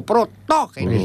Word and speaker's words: Protógenes: Y un obispo, Protógenes. – Hombre, este hombre tenía una gran... – Protógenes. – Con acento en la primera, a la segunda O. Protógenes.